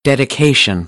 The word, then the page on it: dedication: American English; ə - ɪ